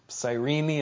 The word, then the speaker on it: Cyrene: He's from Cyrene, and I think that's the way it's supposed to be pronounced.